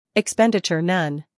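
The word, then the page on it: expenditure: uk ɪkˈspendɪtʃə(r); en-us ɪkˈspendɪtʃər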